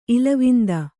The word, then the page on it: ಇಲವಿಂದ: kannada